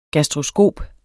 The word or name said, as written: gastroskop